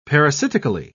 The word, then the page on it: parasitically: British English